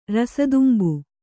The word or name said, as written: ರಸದುಂಬು